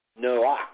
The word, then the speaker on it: Noach: Now, the name of Noah is actually pronounced Noach, and his name actually means to bring comfort, to bring rest, or to bring peace.